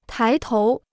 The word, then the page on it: 抬头: tái tóu